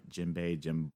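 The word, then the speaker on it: Jinbe: I hope I'm saying that right. Jinbei. – Yeah, that's right.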